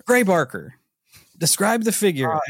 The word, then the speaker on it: Gray Barker: UFO writer Gary Baker described the... I don't know if I said his name right. Is it Gary Baker? Gray Baker. Gray Barker.